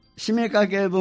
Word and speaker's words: 七五三: で締めかけ分岐ってこれ七五三と書いて締めかけと言うんですって、